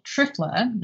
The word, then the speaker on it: Triphala: But it's an herb called Trifla, that's spelled T-R-I-P-H-A-L-A.